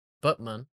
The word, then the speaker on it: Batman: Shortly after this movie's DVD debut, Warner Brothers were under legal action by the city of Batman, Turkey, pronounced Butman, in November 2008.